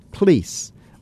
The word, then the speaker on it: police: P-O-L-I-C-E, and I pronounced it police. And I got a note from, I'm guessing it was an older man, a listener. The note came in the form of a postcard, and it said, in this country, the word police has two syllables.